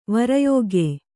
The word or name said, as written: ವರಯೋಗ್ಯೆ